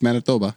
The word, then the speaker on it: Manitoba: No, that's Manitoba.